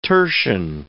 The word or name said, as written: tertian